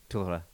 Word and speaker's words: Torre: yes yes and Torre, uh, Torre, look at me, Torre. Can you say it like that? Torre. Very good.